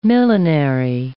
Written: millenary

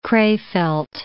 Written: Krefeld

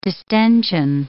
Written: distension

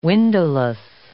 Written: windowless

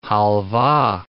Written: halvah

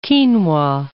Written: quinoa

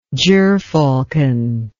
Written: gerfalcon

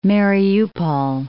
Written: Mariupol'

